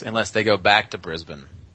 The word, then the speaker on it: Brisbane: That's how they say it in Australia. – Brisbane.